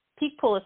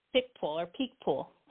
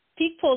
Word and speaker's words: Picpoul: Picpoul. Picpoul is how they pronounce it in France, but Picpoul is how we would say it here in the States. – Okay. – P-I-C-P-O-U-L.